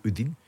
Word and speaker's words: Udine: Yesterday morning, spotted at Glasgow Airport, flying to Rome, did the medical in Rome, travelled to Udine, is that how we're saying it? Yeah, I think the Italians pronounce it Udine.